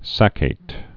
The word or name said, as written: saccate